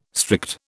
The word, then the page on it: strict: British English